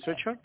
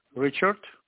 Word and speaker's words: Ryszard: My name is Richard. You can say Richard. Okay. – R-Y-S-Z-A-R-D.